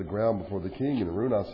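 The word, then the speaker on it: Araunah: And Arunah," which is, I think, the better way to pronounce it, "looked down and saw the king and his servants crossing over towards him.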